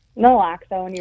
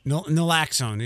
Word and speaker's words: Naloxone: One, people probably saw the headline that opiate overdoses are way up and something about Narcan, Naxalone. Did I say that right? – Naloxone, you were close. – Naloxone, yeah, I transpose the letters.